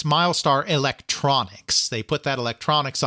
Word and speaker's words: Mylstar: That sounds very much like the kind of thing that's marketing by committee, and it's Miles spelled M-Y-L-S-T-A-R, but it's pronounced Milestar.